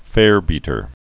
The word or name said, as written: farebeater